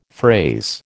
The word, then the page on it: phrase: American English